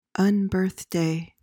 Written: unbirthday

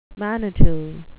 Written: manitou

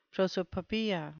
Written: prosopopeia

